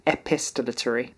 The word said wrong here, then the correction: epistolary: Epistolon... Right, let's try this. – Epistolary. – Oh my god. – Epistolary. – Wow, yeah, I'd added a whole syllable in there.